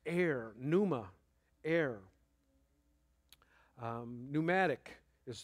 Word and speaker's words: pneuma: The Greek word there is pneuma. It's spelled P-N-E-U-M-A, pneuma.